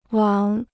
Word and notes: 王: wáng

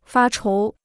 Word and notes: 发愁: fā chóu

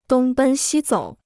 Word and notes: 东奔西走: dōng bēn xī zǒu

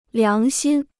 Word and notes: 良心: liáng xīn

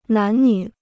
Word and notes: 男女: nán nǚ

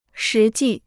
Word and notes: 实际: shí jì

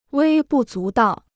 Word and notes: 微不足道: wēi bù zú dào

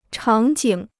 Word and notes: 场景: chǎng jǐng

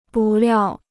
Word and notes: 不料: bù liào

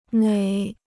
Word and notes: 哪: nǎ

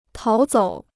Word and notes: 逃走: táo zǒu

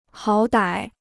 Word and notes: 好歹: hǎo dǎi

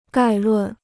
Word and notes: 概论: gài lùn

